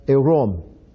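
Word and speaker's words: Erom (עירם): It's pronounced Erom. Erom is Ayin Yud Resh Mem. Erom.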